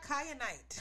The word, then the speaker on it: kyanite: Next, we got kyanite. – Kyanite. – Kyanite. Okay, so that's spelled K-Y-A-N as in Nancy, I-T-E, kyanite.